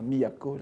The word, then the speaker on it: Miyako: qui est la prononciation du to de Kyoto, n'est-ce pas ? Miyako, la capitale.